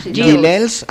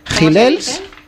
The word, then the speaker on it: Gilels: No, Rachmaninoff es el músico, Gilles, pero el que toca es Gilels. Ah, ¿cómo Gilles se pronuncia? Gilels, lo mejor para el hombre, como Gilets.